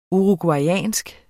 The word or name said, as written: uruguayansk